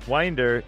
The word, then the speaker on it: Winder: Winder is pronounced with a long I, like the word mind.